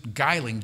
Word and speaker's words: Geiling: Scientist Eugene, I'm going to pronounce it Geiling, G-E-I-L-I-N-G, Geiling of Chicago.